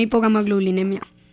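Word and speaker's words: hipogammaglobulinemia: Tiene hipogamma inmunoglobulemia. Lo dije bien, ¿no es cierto? – Sí. Una hipogamma globulinemia.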